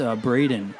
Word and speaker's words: Brandon: Actually, it's Braden. But I spell it Brandon.